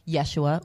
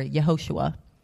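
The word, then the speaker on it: Yeshua (ישוע): In Hebrew, it's Joshua, pronounced Yeshua or Yehoshua.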